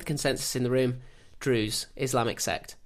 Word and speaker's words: Druze: It's D-R-U... – As you did with pronunciation. – Pronunciation. It's D-R-U-Z-E. So it's either Druze or Druzy. – I don't know. I think I would have said Druze, but...